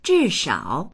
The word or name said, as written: zhi4shao3